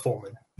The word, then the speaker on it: Follman: Mark Follman.